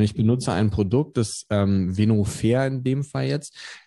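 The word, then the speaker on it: Venofer: Venofair. – Wie schreibt man das? Mit V wahrscheinlich? – V-E-N-O und dann F-E-R. Venofair. So heißt es.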